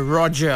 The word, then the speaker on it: Roger: Makes it very, very points it out, saying that it's not like how the French pronounce the word Roger. R O G E R. It's Roger.